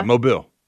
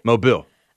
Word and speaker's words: Mobile: Yeah, Mobile. – Say it again? – Mobile. – Okay. I'm hearing M-O-B-I-L-L. – Yeah, Mobile. – Isn't it Mobile? – M-O-B-I-L-L.